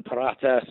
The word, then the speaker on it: Parata: And then Juan Parata sort of rhymes with Barata, the cheese.